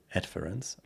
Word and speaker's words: Adference: Es heißt Adference, Betonung auf AD, also Ad.